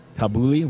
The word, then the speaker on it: tabbouleh: I got tabbouleh, which I often get from a I appreciate the fact that you pronounced it properly.